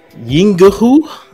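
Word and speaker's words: Yinghu: It's Yingahu. It's literally... It's Y-I-N-G-H-U.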